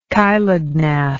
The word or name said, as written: chilognath